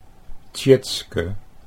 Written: Tjitske

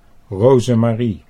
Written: Rozemarie

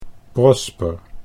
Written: Prosper

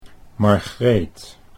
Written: Margreet

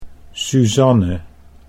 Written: Suzanne

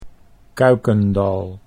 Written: Kuykendal

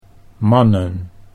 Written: mannen

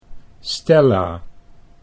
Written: Stella

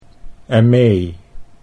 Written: Aimee

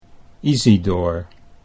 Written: Isidoor

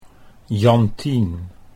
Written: Jantien

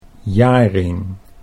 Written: Jaring

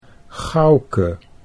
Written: Gouke

